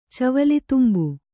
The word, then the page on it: ಚವಲಿತುಂಬು: Kannada